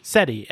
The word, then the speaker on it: SETI: SETI, S-E-T-I, SETI?